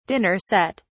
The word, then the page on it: dinner set: British English